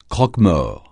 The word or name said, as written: croque-mort